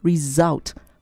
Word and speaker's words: result: 哎，这个字要读作 re z z result 啊，还有 z 的声音哈。